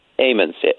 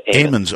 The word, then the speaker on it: Ammons: I mean, here you are, a priest, and you get a call, apparently, from, is it the Ammons family? Is that correct? – Ammons. – Ammons. Okay, Ammons family. That's a long A.